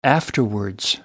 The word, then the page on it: afterwards: American English